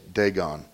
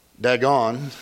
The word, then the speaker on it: Dagon: Someone asked me last week, how do you say Dagon? It's not Dagon. It's Dagon.